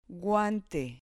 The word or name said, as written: guante